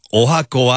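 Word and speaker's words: 十八番: 18 番と書いておはこと読む。 – 垂水区の殿伝中でござるさんからは、私のカラオケ 18 番おはこはこの曲です。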